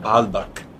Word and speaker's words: Baalbek: It's called Baalbek.